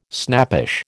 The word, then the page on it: snappish: American English